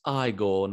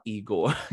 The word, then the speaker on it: Igor: It's his first film with Mel as it's pronounced Igor, not Igor, and a great comedic actor who really is he's like a firework on the screen and so funny.